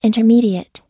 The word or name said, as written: intermediate